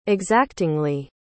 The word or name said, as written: exactingly